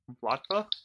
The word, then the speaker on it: Vlatava: Vlatva? – Sure, I like it. – It's spelled V-L-A-T-A-V-A. Vlatva? Vlatva? I think like when you say it fast, it just sounds like Vlatva.